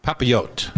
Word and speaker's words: papillote: But salmon and papalote, papalote. – papillote, papillote, papillote with seasonal vegetable ribbon.